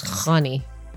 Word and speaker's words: Khani: That's, I mean, one in thing Farsi, nobody says it like this. It's Khani.